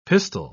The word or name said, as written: pistol